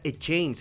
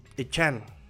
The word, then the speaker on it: Achane: no se pronuncia Echain, se pronuncia Echain, e, Echan, Echan. Es que tiene un ahí como al final, al final, al final tiene un ahí muy, muy, pero no para decir chain como de cadena, sino para decir Echai, eh, chain, ¿No?